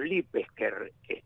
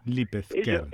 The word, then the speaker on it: Lipezker: eran hijos de los Lipesker, con Z, porque originariamente se escribe con Z. – Lipesker.